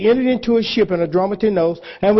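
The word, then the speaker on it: Adramyttenos: And entering into a ship at Adramatinos, that's how that word is pronounced. I had to get my bearings together on it. Adramatinos. It's not spelled the way it sounds. But when you go and you actually hear how the word is spelled or how the word is pronounced in the Greek, it's Adramatinos.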